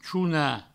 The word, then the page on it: chonar: chouna; provençal